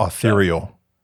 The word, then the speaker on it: ethereal: ethereal. – You pronounced that right.